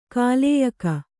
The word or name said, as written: ಕಾಲೇಯಕ